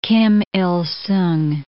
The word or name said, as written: Kim Il Sung